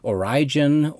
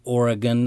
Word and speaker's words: Origen: It was taught by Clement of Alexandria, Origen, or Oregon, I've heard it pronounced both ways, Justin Martyr, and many others, in the Apocryphon of James, which was a manuscript from the Nag Hammadi Library.